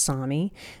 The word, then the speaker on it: Sami: It's spelled S-A-M-I, but it's pronounced Sami.